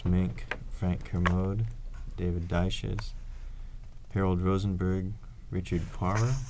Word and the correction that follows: Poirier: is that how you pronounce that? Leslie Fielder, Ihab Hassan, and Buckminster Fuller. – Poirier, I think.